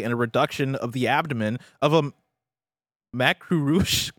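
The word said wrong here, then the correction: macrurous: A reduction of the macrurus crustacean. – This is an R after the C.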